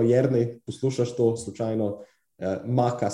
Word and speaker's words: maca: A ja, pa mimo grede, promogu sem jih preveriti, ali se izgovori maca ali maka. Okay, kako nisi, maka.